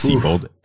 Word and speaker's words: Seybold: I think it's pronounced Siebold, S-E-Y-B-O-L-D, 1999.